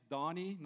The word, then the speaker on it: Danie: My name is Danie, not Barney. I know when I pronounce it over the phone, when I speak to people, they always reply, Barney. I'm now Danie, and after six times, I just let it go.